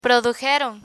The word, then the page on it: produjeron: Spanish